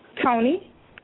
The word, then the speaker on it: Tonie: T-O-N-I-E. – T-O-N-I-E. How you pronounce that? – Tony. – Oh, sounds like you said Tylee. Okay, yeah, I want to say it sound like Tony.